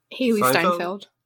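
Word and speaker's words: Hailee Steinfeld: Hayley Stanfield.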